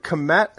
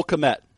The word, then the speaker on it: Kmet: I want to know, do you pronounce it Komet? – Cole Kmet. – Kmet, yeah, I thought so.